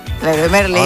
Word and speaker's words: Leroy Merlin: Mensajito, ¿cómo se dice? ¿Leroy Merlin o Leroy Merlin? – Leroy Merlin. – Amigas, son franceses. – No, Leroy Merlin. Merlin, Leroy Merlin, toda la vida.